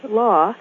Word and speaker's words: Law: My last name, Law, is pronounced just like it's spelled, the same as in law school, law office, or the first law of thermodynamics.